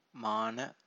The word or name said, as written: mana